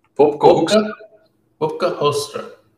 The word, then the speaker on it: Hoekstra: Some Dutch names are really hard to pronounce. And especially if there's a hard G in there or a G. Yeah, foreigners always have some trouble with the G. But also the U, the Hoekstra, yeah, it's difficult to pronounce.